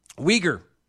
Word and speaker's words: Uyghur: Uyghur.